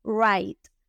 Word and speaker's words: right: La palabra derecho se dice right, right, una R suavecita, la GH es muda y solo se pronuncia la T final, right.